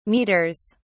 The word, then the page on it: metres: British English